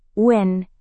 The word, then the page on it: un: indonesian